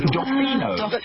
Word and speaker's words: Dofino: Doffino. – So spell that for me. – D-O-F-I-N-O.